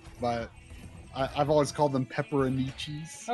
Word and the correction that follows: pepperoncini: Oh, okay. Pepperoncini.